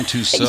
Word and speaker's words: Tussaud: Madame Tussaud. – I knew that was a setup. – The wax museum. I don't speak French. – The wax. No, and you shouldn't. We should pronounce it like Americans.